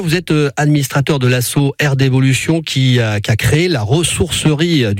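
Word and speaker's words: R'D'évolution: C'est le cas de notre invité de ce mercredi 26 août, Sébastien Pichot, administrateur de l'association Air d'Évolution basée au Vigan.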